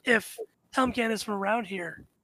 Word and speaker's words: Helmgant: But he responds, "I am Helmgat." – How would you spell that? – H-E-L-M-G-A-N-T.